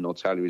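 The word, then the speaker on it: Norrtälje: And Nortalia is spelt Nortalje.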